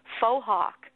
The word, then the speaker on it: faux hawk: Now, a word that is much more evocative and easy to guess what it means is faux hawk, spelled F-A-U-X-H-A-W-K, faux hawk. Since it rhymes with mohawk and it starts with faux, we can guess that it means a sort of a fake mohawk hairstyle.